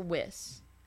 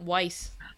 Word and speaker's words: Weiss: Is it Weiss or Wiss? – Weiss? – Yep, Weiss. – I told you. – All right. Yeah, I think I remember it being Weiss because I've been pronouncing it Wiss in my head this entire time, and then they said Weiss, and I was like, oh, I was very wrong.